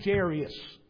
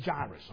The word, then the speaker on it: Jairus: And just so you know, being transparent with you this morning, I will want to continue calling him Jairus. But that's not how you say his name. There is no I after the R. It comes before the R. So it's Jairus.